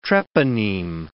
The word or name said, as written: treponeme